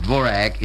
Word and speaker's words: Dvořák: One cannot listen very long on the radio without hearing the compositions of Antonín Dvořák, who is pronounced Dvorak in America, and those of Rudolf Frimer.